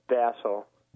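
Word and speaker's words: Dassow: Dassau. – Dassau. – Yeah. – Okay. – There's a W on the end, but it, don't make any sound with it.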